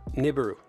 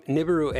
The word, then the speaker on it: Nibiru: I like how the Sun has, in their article, they've got a picture of supposedly Nibiru. Yeah, there we go. I said it right that time. All right. Nibiru, and it's kind of on a collision course with the Earth and the Moon.